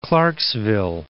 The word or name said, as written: Clarksville